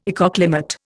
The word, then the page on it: ecoclimate: Indian